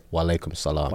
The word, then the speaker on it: wa alaikum salam: Can you teach me how to say it? – Assalamu alaikum. – Assalamu alaikum. – And then you would say wa alaikum salam. – Wa alaikum salam.